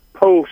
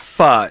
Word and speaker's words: pho: Yeah, it's actually pronounced pho, and it's spelled pho. – Right. – So a lot of people call it pho, but it's pronounced pho, and they do a nice pho there.